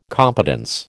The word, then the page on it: competence: American English